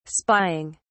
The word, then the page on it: spying: British English